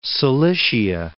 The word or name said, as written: Cilicia